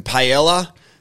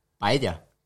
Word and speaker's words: paella: Here in Australia, we say, hey, you want some paella? Do you say it correctly how we should be saying it, please? – Paella. – So you say paella? – Paella. – That's correct.